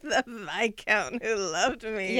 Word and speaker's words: Viscount: That reminds me, when I was reading these books, and the first one I read was The Viscount Who Loved Me. From watching the Netflix series, mm-hmm. I've learned that's the correct pronunciation, yes. Because in my head I was saying Viscount.